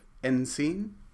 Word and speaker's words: NCINE: So I'm alluding to a project that I wanted to talk to you about called Encine. – Yeah. – And that's spelled N-C-I-N-E, but there will be links to it in the show notes.